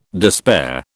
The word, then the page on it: despair: British English